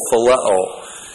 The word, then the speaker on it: phileo: And the word that Peter responds is the Greek word phileo. P-H-I-L-E long O, phileo.